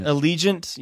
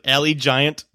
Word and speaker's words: Allegiant: Allegiant. Yeah, sure. That's what. Okay, it makes sense now. Yeah, I was like Allie Giant.